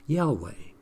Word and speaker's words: Yahweh: And the word Lord, usually, almost always, but not completely, is translated in the original Hebrew as Yahweh. Some will use the pronunciation Jehovah, but that's not as accurate. Yahweh.